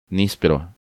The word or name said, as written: Nispero